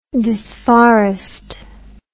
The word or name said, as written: disforest